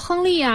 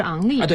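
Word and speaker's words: Henry: 哎，对对对，还有赵军说亨利啊，上海解说是昂利啊。对，其实这个是按这个发音，法语的发音。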